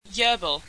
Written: Yurble

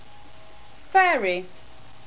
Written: faerie

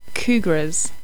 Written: kougras

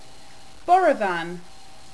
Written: borovan

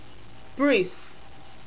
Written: bruce

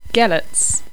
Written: gelerts

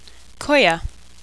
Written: Koya

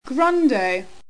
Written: grundo

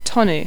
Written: tonu

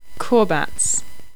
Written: korbats

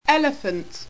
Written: elephante

